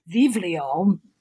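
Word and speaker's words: Vivlio (βιβλίο): You could, you know, vivlio, beta, iota, beta, lambda, Omicron, Vivlio, pronounced, you know, the B's, the betas are pronounced like V now.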